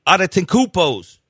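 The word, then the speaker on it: Adetokounmpo: Then they made a group of the Cavs players, and then they made a group of the the Giannis brothers, the Adetokounmpos. All three of them on there. I can't believe I just said that right.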